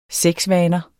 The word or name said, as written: sexvaner